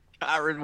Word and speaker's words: Kyren: You can't even say his name. – Kyren. – Is it Kyren? – Kyren Williams.